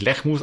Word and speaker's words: Lehmus: Ich habe mir extra sagen lassen, der Nachname spricht sich Lechmus aus.